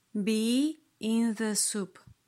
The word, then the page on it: be in the soup: slow